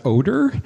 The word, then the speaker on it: Odyr: his name is pronounced Odyr. It's O-D-Y-R.